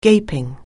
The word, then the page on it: gaping: anglais